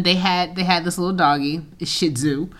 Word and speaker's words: Shih Tzu: Shih Tzu. Shih Tzu. Like shit on Sue.